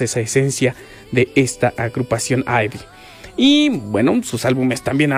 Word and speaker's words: IVE: Y de ahí también ahorita vamos a hablar de su nombre, porque Ivy, porque se escribe I-V-E, podríamos decir que sí se pronuncia Ivy,